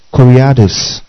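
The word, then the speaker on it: koriakos (κυριακός): Now, the English word church is derived from the Greek adjective koriakos.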